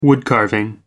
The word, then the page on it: woodcarving: American English